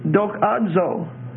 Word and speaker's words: doxazo: The word glorified is doxazo, D-O-X-A-Z-O. And that last O is a long O, D-O-X-A-Z-O.